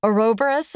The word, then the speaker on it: Ouroboros: This one just felt to me like a Ouroboros of memory.